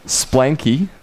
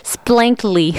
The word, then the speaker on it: Splanky: was Solomon Douglas on the Swingin' Blues Radio Show, and we just played three Solomon Douglas in a row, and you heard Splanky, which I mispronounced yesterday in a funny way. – Yes, you said Splankly.